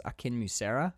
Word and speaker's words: Akinmusire: His name's um, I, I always say his last name wrong, but his name is Ambrose Akinmusire, I think is how you say it. His last name is A K I N M U S I R E.